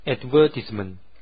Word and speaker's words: advertisement: 在英式英语当中啊，它这个 advertisement 读成 advertisement。